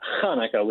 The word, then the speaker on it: Chanukah: So, well, in, in, the Hebrew language, it's Hanukkah with the CH, okay. uh, C H A N U K A H. Uh, the reality is that a lot of folks struggle with the H pronunciation, and so Hanukkah and Hanukkah have become pretty interchangeable in today's day and age.